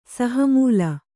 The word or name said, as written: ಸಹಮೂಲ